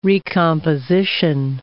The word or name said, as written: recomposition